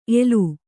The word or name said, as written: ಏಲ